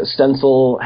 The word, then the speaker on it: Stencyl: It's spelled it's spelled kind of funny S T E N C Y L.